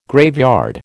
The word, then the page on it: graveyard: American English